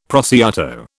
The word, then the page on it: prosciutto: British English